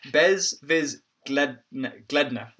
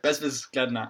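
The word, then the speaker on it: bezwzględna: It's like bez viz. Gledna, right? Bezvis Gledna. And in the middle of that, there's a Z followed by a W, followed by another Z, followed by a G, followed by an L.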